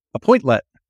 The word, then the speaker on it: Appointlet: Appointlet? Am I correct? – Appointlet, yeah.